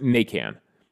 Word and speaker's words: Naquin: But yeah, he was like, please keep calling me Tyler Naquin. It's fine. But if you're down south, if you're in Louisiana, it's pronounced Nacan.